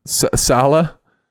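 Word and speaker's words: Saleh: You know, how do you pronounce his last name? I butchered it last time. – Sala. – There we go.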